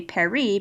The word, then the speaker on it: Paris: And then for Paris, you would say Paris because you don't pronounce the final S.